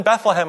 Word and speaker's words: Bethlehem: It's two syllables. – Bethlehem. Yes. – Why is that? – Bethlehem. – Well, if you think about Bethlehem, it's like Bedlam. Same idea, sort of Bedlam, Bethlehem.